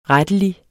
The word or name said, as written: rettelig